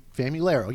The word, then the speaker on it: Famularo: Uh, and from, excuse me, Fam- Famularo. Yeah, I said it right.